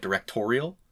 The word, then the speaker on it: directorial: And this is your directorial, is that how you say it? Directorial? Like editorial, directorial debut.